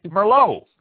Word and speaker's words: Merlot: Merlot. M-E-R-L-O-T. Pronounced Merlot.